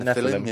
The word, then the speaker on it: Nephilim: So the book of Genesis 6:4 tells stories of the Nephilim. I think that's how you say it. Nephilim? – Nephilim.